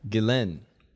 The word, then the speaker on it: Ghislaine: Ghislaine. – Ghislaine. – Yeah. – Okay, Ghislaine.